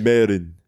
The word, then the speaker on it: Myron: But his name is spelled Myron. – Maybe I think it's the accent. – Myron. – So no, Myron. He wasn't saying Myron. He was saying Mayron. – Listen, Mayron.